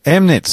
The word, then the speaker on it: Chemnitz: Chemnitz. So the CH is completely silent. – No, Chem.